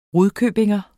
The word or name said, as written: rudkøbingenser